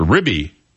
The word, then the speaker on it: RBI: an actual word pronounced ribby.